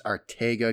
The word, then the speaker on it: Arcega: I know he'll go by Arcega. Ross and company said it's in the King's Spanish. It's Ortega because the C is pronounced as a T.